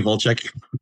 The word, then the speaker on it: Walczak: You could do Walchek.